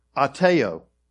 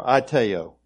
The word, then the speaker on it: aiteo: In the Greek language, the word ask there is A-I-T-E-O. Did I put that? Okay. Ateo, or Ateo is how it's pronounced.